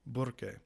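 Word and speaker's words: Burque: Burke, pronounced with a rolling R as it had been for literally centuries.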